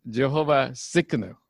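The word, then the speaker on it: Tsidkenu: It's like Chinese have this T-S name, right? Tseng, right? So it's like the way it's pronounced is Jehovah Tsikanu, okay? You can barely hear the T.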